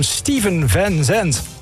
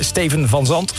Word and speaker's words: Steven Van Zandt: Met de naam Steven Van Zandt. Als je het Nederlands zou uitspreken, zou je denken Steven Van Zandt.